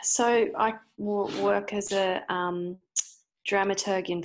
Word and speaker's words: dramaturg: I do want to touch base because I feel like I'm going to get this dramaturgy. No, is that how you say it? – Dramaturgy, soft G. And then if you say you are a dramaturg, it's a hard G, or dramaturge.